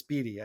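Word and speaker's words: Beaty: Or Matt Beattie, it's Beattie. I finally got his name right.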